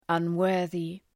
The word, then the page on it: unworthy: ʌn’wɜ:rðı